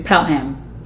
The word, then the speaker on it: Pelham: Palom 112? Was that it? Did you see that movie? – Pelham. Yes. – I told you. I can't pronounce shit. Pelham 123.